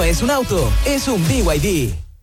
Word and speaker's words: BYD: ¿Los VIB? – ¿VIB? ¿No es BYD? – Nada que ver. Se escribe BYD, pero se dice BYD.